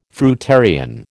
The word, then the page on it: fruitarian: American English